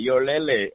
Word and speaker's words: Yolele: You roll it together, Yolele. Is that how you pronounce it? – Yes, you said it right, Yolele.